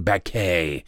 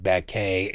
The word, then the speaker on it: Baquet: The executive editor of the New York Times released a statement, Dean Baquet. You know he's a serious person because he pronounces his name that is spelled Beckett with a French ending to it.